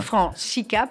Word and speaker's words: Francica: Ça s'écrit Francica pour ceux qui ne parlent pas italien comme moi, mais on dit Antonio Francica.